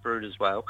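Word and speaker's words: Bryde's whale: The species that you were looking at in particular is, correct me if I'm wrong, it's the bride's whale and the big eye, the reef fish? – Yeah, so it's pronounced brooder's whale.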